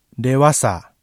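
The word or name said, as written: dewasa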